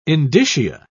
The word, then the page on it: indicia: British English